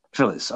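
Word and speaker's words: Philles: Phillies or Phyllis? How do you pronounce that? – Phyllis.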